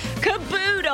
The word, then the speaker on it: caboodle: Thanks for being with us as we discuss this morning, of all things, a flash in the past of, did I say it right? Cadoodles? – No, you have not been saying it right all morning. – What is it? – Caboodle. – Oh, the B. Caboodle. – B. Caboodle. – It sounds more natural to say cadoodle.